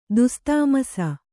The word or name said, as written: ದುಸ್ತಾಮಸ